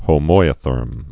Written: homoiotherm

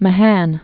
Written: Mahan

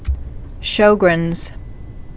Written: Sjogren's syndrome

